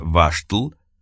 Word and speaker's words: Wastel: Слово «Wastel» по-тирольски произносится «Ваштл», в данном случае имеет два значения.